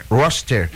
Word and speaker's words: Worcester: Worcester.